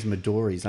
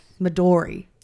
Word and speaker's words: Midori: Oh, you're one of these Maduris, aren't you? Not Maduri. – Maduri. – Maduri's the worst. Midori. – It's Midori. – It's the Japanese word for green.